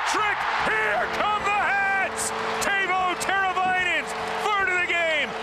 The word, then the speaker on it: Teuvo: Teuvo Teravainen. – Teuvo.